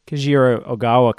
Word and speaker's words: Kajiro Ogawa: How did Kajiro Ogawa come on your radar? – Great pronunciation.